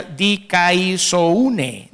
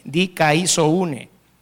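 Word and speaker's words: dikaiosyne: Viene de un vocablo griego que se pronuncia dikaisoune, dikaisoune.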